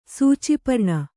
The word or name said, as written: ಸೂಚಿಪರ್ಣ